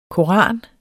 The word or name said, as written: koran